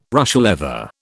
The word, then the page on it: Russia leather: British English